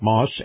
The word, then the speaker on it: Maus: Now, Moss is spelled M-A-U-S,